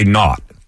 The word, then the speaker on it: naught: Not with the way you say not. Is a tip-off. – Yeah, well, it's spelled N-A-U-G-H-T. – Exactly. N-A-U-G-H-T.